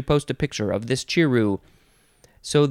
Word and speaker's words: chiru: Next we have chiru. Chiru. It's pronounced just like it's spelled. C-H-I-R-U. Chiru.